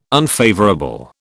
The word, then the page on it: unfavourable: British English